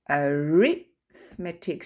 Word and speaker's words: arithmetic: Mais on dit arithmetic, c'est-à-dire qu'en fait l'accent tonique est sur le deuxième wagon et pas le troisième avant-dernier.